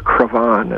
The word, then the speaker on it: Cravan: Yeah, he gave me a biography he'd written about this man, Arthur Cravan, as he pronounces it, although looks like Craven to me. – Yeah, it looks like Craven to me. I pronounce it Craven. – Perhaps Cravan is the French.